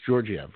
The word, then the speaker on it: Georgiev: Did I say that right? It's Georgiev, right? – Mm. – Yes? Was that your agreement? – Yeah, sure.